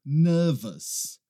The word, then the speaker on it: nervous: O que acontece é que aqui as pessoas têm dificuldade com aquele finalzinho também, O-U-S. E aí elas acabam pronunciando nervous, I'm nervous. A gente não pronuncia aquele U. E aí vai outra dica para você. Toda vez que você vê uma palavra terminando com O-U-S, você vai falar us. Us. Nervous. Nervous.